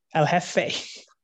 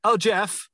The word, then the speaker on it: El Jefe: And how do you pronounce it? El Jefe. – El Jefe. – I thought so. I didn't want to say El Jeff. – Oi, Jeff. – Like, hello, Jeff.